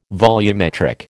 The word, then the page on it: volumetric: American English